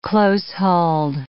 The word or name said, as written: close-hauled